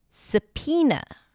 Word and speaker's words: subpoena: The next word is subpoena. Subpoena. The word is spelled with a B sound and then there is an O there somewhere.